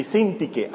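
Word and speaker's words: Síntique: Y Sintique, así se pronuncia, Sintique. Pero como dice la mano Pulido, Sintique, ¿verdad?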